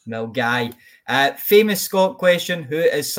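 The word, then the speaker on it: Milngavie: Bash, do you know how to pronounce this town? – Mungavi. – That's what I said. – It's Mungai.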